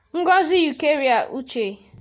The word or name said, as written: Ngozi Eucharia Uche